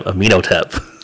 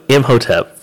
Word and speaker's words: Imhotep: So I just discovered today that I've been pronouncing this character's name wrong for my entire life. – Nice. – Imhotep. I've been calling him Amenhotep. Completely wrong. I can see that now, but Imhotep from The Mummy.